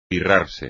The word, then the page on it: pirrarse: espagnol